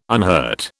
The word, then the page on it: unhurt: British English